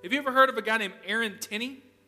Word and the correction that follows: Stinnie: I'm sorry, I got his name wrong. Aaron Stinnie.